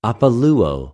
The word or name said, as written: ἀπολυω